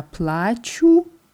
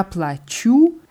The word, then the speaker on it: плачу: Wir haben nur gesagt, dass man bei der Ich-Form ja плачу besonders auf die Betonung achten muss und die ist hier wo? – Auf dem U am Ende? – Auf der letzten Silbe auf dem U, weil ja plaču was bedeuten würde? – Ich weine. – Richtig. Ja plaču heißt ich zahle.